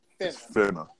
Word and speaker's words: finna: Like or it's like what you Frenna do? Like no R. There's no R in it. Finna.